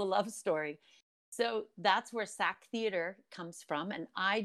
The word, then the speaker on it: SAK: You ended up leaving home at 19 to join the SAK Theater. Can you tell me about that experience? Sure. – So it's called SAK, SAK Theater.